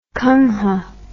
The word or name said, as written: kangha